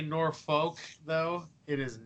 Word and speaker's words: Norfolk: It's Norfolk. – There is no R in the second syllable.